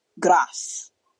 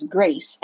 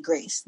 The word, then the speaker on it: Grace: I had a professor who called me Gras, and I understood that he came from a different cultural background, French African. Cool, fine. That's how you would pronounce this word where you are from. But this is my name, and my name is Grace. And I expect that you will call me Grace the same way you expect that I will call you Professor, not AO.